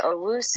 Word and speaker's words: Owusu: If I was them and Parsons is off the board, I'm taking whatever his face is from Notre Dame, Owusu Koramoah, however you pronounce it. – Jeremiah Owusu. – That's how you say it.